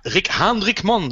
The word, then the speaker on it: Rick Haanrikman: Rick, Rick Haanrikman. Spreekt het goed uit? Zeg je het zo? Ja, ja, nee. Heel, heel knap. heel goed uitgesproken.